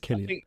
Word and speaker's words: Cillian: Is it Cillian or Cillian? I think it's Cillian. – I think you're right. I think it's a hard C.